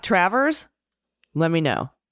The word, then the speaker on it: Traverse: The correct pronunciation is Traverse City. I, I don't know if this is Traverse. Traverse? Like like there's because there's an apostrophe, so we got a correction to a correction like a pronunciation, but now we're not sure how to pronounce the correction. So Traverse City? I think it's Travers.